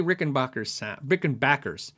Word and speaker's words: Rickenbacker: I love the way Rickenbackers sound. It's like backpacker. I did a how do you say video and I asked them how do you say it and they said Rickenbacker.